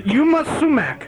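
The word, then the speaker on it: Yma Sumac: Y-U-M-A? – No, just Y-M-A. Just Y-M-A. – Okay. – And then Sumac, S-U-M-A-C, Sumac.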